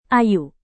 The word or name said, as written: ayu